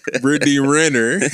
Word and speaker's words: Brittany Renner: Brittany Renner is a Brittany Winner now. – She is Brittany Winner. Brittany Renner. – You said it right.